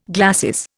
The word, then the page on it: glacis: Indian